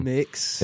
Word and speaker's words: Mx: I don't know how you pronounce that, but mix.